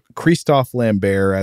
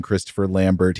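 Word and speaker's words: Christophe Lambert: Right at the top of the cast list here, Highlander 2 has the same star as the original Highlander, which is Christoph Lambert, as I've been calling him. That is the sort of the French pronunciation of his name, but it's okay if you call him Christopher Lambert. He said that in interviews. He doesn't mind the American pronunciation either.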